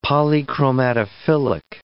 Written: polychromatophilic

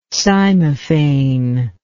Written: cymophane

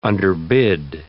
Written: underbid

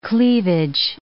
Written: cleavage

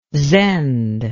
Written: zend

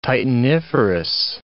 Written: titaniferous

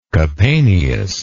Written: Capaneus

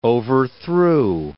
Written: overthrew

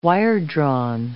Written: wiredrawn

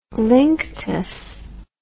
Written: linctus

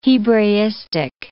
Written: Hebraistic

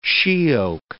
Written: she-oak

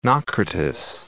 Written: Naucratis

